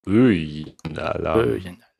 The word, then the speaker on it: Böen-Alarm: Ich bin sehr gespannt, wie ihr Böen-Alarm aufschreibt. So hier steht, das würde man aussprechen, so wie mit einem Ö. Also so wie die Bö, aber es heißt Schauer, es heißt Regen. – Böen-Alarm.